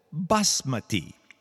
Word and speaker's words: basmati: There is something called basmati.